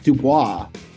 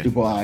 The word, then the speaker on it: DuBois: Dak DuBois. – Dak DuBois. Okay. – DuBois, yeah. – Just like Marge Simpson's last name, DuBois.